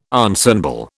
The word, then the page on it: ensemble: British English